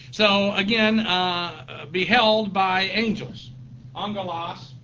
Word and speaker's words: Angelos: A-N-G-E-L-O-S. Again, it's actually two gammas in the Greek, but under the Greek rules of pronunciation, when you have two hard consonants like that, the first one is pronounced as a nu, an N.